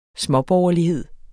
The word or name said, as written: småborgerlighed